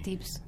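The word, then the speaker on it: Twebs: Hace unos meses me obsesioné con un pibe que se llama Tips, un productor, con el disco se llama Ardor. – ¿Y cómo se escribe? tips. – Tips. T-W-E-B-S, B larga.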